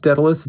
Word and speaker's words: Daedalvs: It's pronounced Daedalus. It's spelled Daedalvs. The reason is because in Latin, U's used to be spelled like V's, and also because it was easier to get that handle on Diablo 2. – Yeah. – Than just with a U.